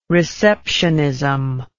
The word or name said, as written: receptionism